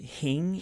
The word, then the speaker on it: hing: Hing? Did I say that right? – Yeah, you said it just right.